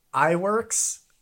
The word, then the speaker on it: Iwerks: Iwerks.